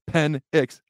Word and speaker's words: Penix: Okay, Michael Penix, all right, Penix, I'm saying it right today.